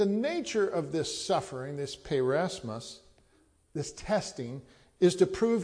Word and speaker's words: peirasmos: The word is peirasmos. I'll spell it P-E-I-R-A-S-M-O-S, peirasmos.